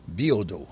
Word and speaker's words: Biodeau: Biodeau, c'est comme ça que c'est prononcé.